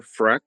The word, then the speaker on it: Freck: My name is Peter Freck. That's spelled F-R-E-C-K.